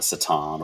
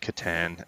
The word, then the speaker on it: Catan: And they were still talking about the Satan or Catan and how they betray. – Yeah, what do you think the correct pronunciation of that is? They call it the Catan. – Catan. Yeah, it's Catan.